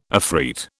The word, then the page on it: afreet: British English